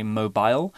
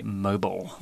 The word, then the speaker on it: mobile: Right, you might hear two different pronunciations of this mm -hmm. word. In the UK in British English, we tend to say mobile, whereas in the US, they might say mobile.